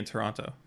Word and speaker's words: Toronto: Well, they don't pronounce the T in Toronto.